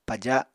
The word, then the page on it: paja: Apatani